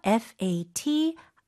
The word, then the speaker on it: fatigue: 스펠링이 좀 특이해요. Fatigue. – 네. T-I의 강세가 굉장히 많이 들어가네요. – 맞아요. Fatigue.